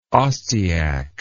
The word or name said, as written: ostyak